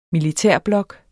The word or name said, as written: militærblok